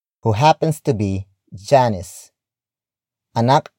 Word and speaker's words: anak: And that noun is anak. Anak. Anak. Anak is spelled as A-N-A-K.